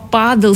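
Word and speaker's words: pádel: И так появилось слово «падл» с ударением на «а», официальное название нового вида спорта, далекий 69-й год.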